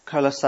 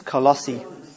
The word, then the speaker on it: Colossae: He says, to the saints and the faithful brethren in Christ who are at Colossae. I don't think I'm saying that right. I think it's Colossae. Colossae.